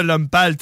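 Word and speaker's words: Lompal: Fait qu'on s'envoie une toune de l'homme pâle.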